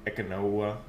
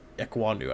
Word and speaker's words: Ekwonu: Ekwonu, I believe, is. – Ekwonu? – Okay. Yeah, Ikem Ekwonu, I believe is how I've heard people pronounce it.